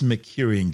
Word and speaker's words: Mercurian: These Mercurian glaciers, and yes, that's how you say it, distinct from Earth's, originate from deeply buried volatile-rich layers exposed by ancient asteroid impacts.